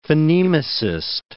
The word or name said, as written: phonemicist